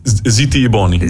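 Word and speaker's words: Zitti e buoni: Zitti e Bioni? – Zitti e Boni.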